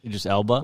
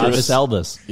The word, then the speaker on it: Idris Elba: How do you say it? Idris Elba. – Idris Elba. – What'd you call him? – Elbus. – Ibis. Idris. – Ibis Elbus. Idris Elba.